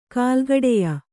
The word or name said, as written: ಕುಲಗಡಿಯ